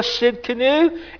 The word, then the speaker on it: Tsidkenu: The T is silent. It is Jehovah Tsidkenu.